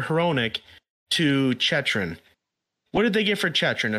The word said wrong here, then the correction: Chychrun: Sorry, Chychrun.